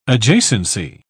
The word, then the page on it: adjacency: British English